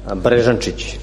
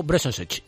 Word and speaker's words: Beresancic: Mira cómo se pronuncia Beresancic. – Beresancic. Es difícil poco para vosotros. – Raiko Beresancic. – Beresancic. Pues así es como se dice el nuevo lateral que viene a competir con Rica.